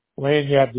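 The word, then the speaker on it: Lagniappe: Is it pronounced Lanyap? – Lanyap, the G is silent.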